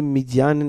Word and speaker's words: Midian: And and I said to him, uh, how do you say Midian in Arabic? He's like Midian, and I'm like, so this is about about where Jethro comes from.